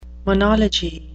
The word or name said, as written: monology